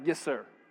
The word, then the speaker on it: yetser: So the word mind here, the Hebrew word that they're using for mind, I can't even pronounce it, I'm going to spell it for you, is Y-E-T-S-E-R. It's like, yes, sir, something like that.